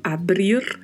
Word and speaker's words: abrir: Na sequência, o verbo abrir, verbo de terceira conjugação, que tem o mesmo significado e é escrito do português, no entanto, a pronúncia tem a particularidade do R aí com o vibrato. Não podemos nos esquecer disso.